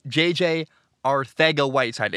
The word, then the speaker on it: Arcega-Whiteside: In the 2019 draft, Philly drafted, the proper pronunciation is J.J. Arcega-Whiteside. It is spelled Arcega. I had a friend who had the last name. His name was Jose Arcega. But this apparently is Arcega, J.J. Arcega-Whiteside. I'm told there's a T-H sound.